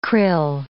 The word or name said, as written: krill